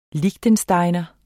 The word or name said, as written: liechtensteiner